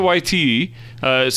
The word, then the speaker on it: Waititi: Yeah, Taika Waititi stars and directs and wrote this thing and produced this thing.